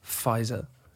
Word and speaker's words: Pfizer: I had my second Pfizer. – Pfizer? – I thought for a while it was the visor with a V. – Mm-hmm. – It's a silent P.